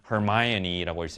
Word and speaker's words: Hermione: 누구의 역할이냐 하면 of Hermione, 헤르미온느라고 우리말로는 읽었지만 Hermione라고 읽습니다. Hermione Granger, 헤르미온느 역의 역할의 시험을 봤습니다.